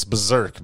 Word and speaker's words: BRZRKR: I want to say in October, I think the article said, called, it's BRZRKR, so it's Berserk, basically.